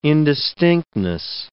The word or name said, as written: indistinctness